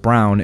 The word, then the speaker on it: Braun: And finally, OP says that Braun is pronounced Brown in German, so people who know history will know.